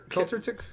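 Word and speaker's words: Kvelertak: I don't know how to say this name. Kelter Tech? – Kelter Tech.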